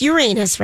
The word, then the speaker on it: Uranus: And I always say that wrong because they're made of gas. – Isn't it Uranus? – It's Uranus, right? – Yeah. Yes.